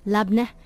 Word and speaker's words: labneh: So there was this beautiful beetroot mint labneh. Did I say it right? – Yep.